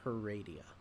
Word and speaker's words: Heredia: Heredia.